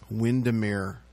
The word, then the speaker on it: Windermere: During the 1950s and in the early 1960s from their home in Widmer. Windermere. You know, the listening audience, Steph, doesn't know that it's pronounced Windermere.